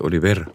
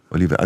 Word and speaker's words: Oliver: José Oliver. – Oliver. – Genau. – Oliver, also dann noch ein bisschen mehr auf die letzte Silbe. – Genau, der Akzent und dann das R gerollt.